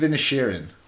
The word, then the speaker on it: Inisherin: The Banshees of Inisherin. – You sure that's how you said it? – Yeah, I'm positive. That's about how you say it.